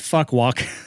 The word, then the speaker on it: Phu Quoc: You can also pronounce it Fuck Walk, which I have many jokes with that.